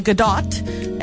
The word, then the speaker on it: Gadot: The best part of that movie was Gal Gadot. And yes, it is pronounced Gadot.